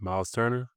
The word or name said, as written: Myles Turner